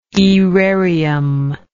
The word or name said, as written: aerarium